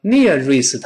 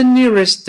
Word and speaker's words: nearest: 同样，中间这个字母 r 要勾回来，不要读成 nearest，它不是这么读的。 nearest，nearest，near，勾回来，再跟 rest 连读，慢慢跟老师读一遍。 Where is the nearest department store？Where is the nearest department store？